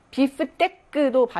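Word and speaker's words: bifteck: 비프테크도 발음이 나는데 요거 조심할 거는 그 비프스테이크라고 우리가 얘기하잖아요. 근데 프랑스에서는 비프스테이크에 스가 없어요. 철자에 비프테크라고 합니다.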